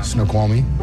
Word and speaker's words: Snoqualmie: Snoqualmie. – Snoqualmie. Easy. – Well done. – Snoqualmie.